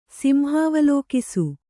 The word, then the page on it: ಸಿಂಹಾವಲೋಕಿಸು: kannada